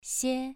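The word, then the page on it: xie: tone 2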